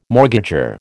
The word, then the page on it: mortgager: American English